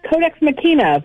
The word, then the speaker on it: Codex Machina: Her upcoming book is called Codex Machina. How am I pronouncing that, Heather? Pretty good? – Codex Machina, but I'll take it either way.